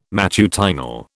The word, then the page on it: matutinal: British English